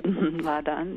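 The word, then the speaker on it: Láadan: And you write about one language called La'adan. Is that how you say it? – La'adan, yeah.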